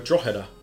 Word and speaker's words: Drogheda: I've been to Dublin and I've been to Drogheda.